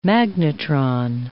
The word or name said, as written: magnetron